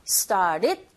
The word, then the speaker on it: started: 그래서 started 이런 식으로 발음이 되고 뒤에 it이 또 이어지죠. 이런 현상에서도 또 모음과 모음 사이에 들어있는 d 사운드 자체가 r 소리 가깝게 들려지는 현상이 벌어져서 결과적으로는 started it 이렇게 발음하셔야 돼요.